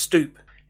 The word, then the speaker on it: stoep: S-T-O-E-P, which is pronounced stoop.